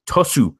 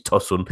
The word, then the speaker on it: Tosun: Monaco's trip to Turkey turned into disappointment as, despite going ahead thanks to Ronnie Lopez, a Cenk Tosun penalty, I've butchered that name, it's Tosun, helped Besiktas draw 1-1 and push the league and champions on the brink of elimination.